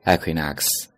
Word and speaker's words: Equinox: 春风我们叫做 Spring Equinox，注意啊，第二个词读作 Equinox，它是一个名词，昼夜平分点的意思。